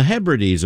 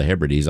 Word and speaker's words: Hebrides: either knew about the Hebrides Islands or was familiar with classical music if they pronounced the name of the boat Hebrides instead of Hebrides.